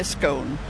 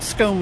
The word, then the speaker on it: scone: Definitely scone. It's a Scottish word, and the Scottish pronunciation is scone. It's only the English who say scone.